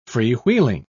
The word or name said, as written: freewheeling